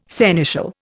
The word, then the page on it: seneschal: Indian